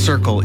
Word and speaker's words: CRCL: CRCL, pronounced Circle, is a mobile, community-led crisis response service in the Comox Valley.